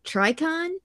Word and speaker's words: Trikon: They revolted against the inhumans, and Medusa ended up fighting their warriors called, you're going to have to tell me if I'm pronouncing this right, Trikon? – I mean, that's how T-R-I-K. – That's how I pronounce it.